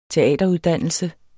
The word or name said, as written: teateruddannelse